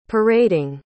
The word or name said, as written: parading